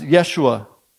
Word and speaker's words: Yeshua: And it's interesting to me that his name is Joshua, which in Hebrew you pronounce as Yeshua.